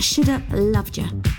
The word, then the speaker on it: Shoulda Loved Ya: Have a good rest of your day, because I'm going to leave you now with Narada, Michael Walden, and that Shoulda Loved Ya. Actually, you have to say it like this. Shoulda loved ya.